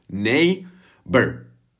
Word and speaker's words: neighbor: Já vím, že v tom je gh a vypadá to ošklivě, ale gh velmi často se prostě nevyslovuje. Takže to vyslovíš úplně stejně, jak kdyby to tam nebylo. Neighbor. A zase ne, není to bor, ale br.